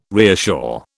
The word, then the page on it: reassure: British English